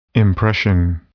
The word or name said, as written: impression